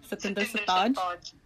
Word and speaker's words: Satinder Sartaaj: Aunties do love Satinder Sartaaj. I can't even say his name properly. – Satinder Sartaaj. – You go. Said it nicely.